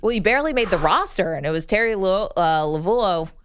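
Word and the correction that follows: Lovullo: It's Lavello, but there's a U in there that you don't pronounce and it throws you off.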